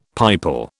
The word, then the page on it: pipal: British English